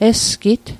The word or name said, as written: esgit